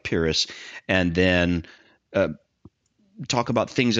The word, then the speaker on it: Pyrrhus: And you reference Pyrrhus. I'm going to say Pyrrhus. – That's the way I've pronounced it simply because of the term he is associated with, the Pyrrhic victory.